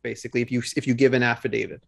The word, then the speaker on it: affidavit: Affidavit? – Yeah, affidavit. – Yep. That's how it's pronounced? – Yeah, yeah.